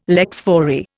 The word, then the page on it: lex fori: Indian